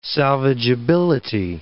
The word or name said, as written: salvageability